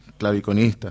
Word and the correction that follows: clavecinista: Clavecinista. – Clavecinista, sí, que toca la clave.